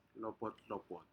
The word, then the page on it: lopot lopot: Aneityum